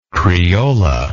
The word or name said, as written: criolla